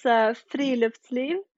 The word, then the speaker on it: Friluftsliv: Could you tell me how you say it again? How do you say it? – Yeah, it's Friluftsliv. – Okay, I definitely can't say it like that. – Okay, so like Friluftsliv. That's the Americanized pronunciation.